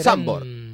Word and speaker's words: Zandvoort: Zandvoort. Con Z. – En neerlandés.